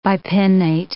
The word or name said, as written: bipinnate